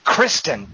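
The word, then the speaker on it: Kristin: I'm head over heels in love with that movie, and specifically with what I had already thought that Kirsten Scott Thomas, am I saying it right? – Kristen, dadgummit.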